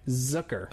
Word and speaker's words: Zucker: I think it's actually pronounced like hooker, like Zucker.